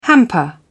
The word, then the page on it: hamper: British English